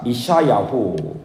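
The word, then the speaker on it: 이샤야후 (ישעיהו): 그래서 히브리어는 저를 한번 따라해보겠습니다. 이샤야후. 히브리어는 이사야를 이샤야후라고 합니다. 여호와께서 뒤에 야후는 야훼 하나님, 야훼 하나님이 이샤 구원하신다 이런 뜻이에요.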